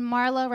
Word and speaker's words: Marla: And Marla rhymes with Darla, like from the Little Rascals.